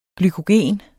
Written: glykogen

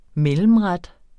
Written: mellemret

